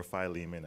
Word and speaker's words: Philemon: Philemon, or Philemon as some pronounce it, had a slave named Onesimus, whose name means useful.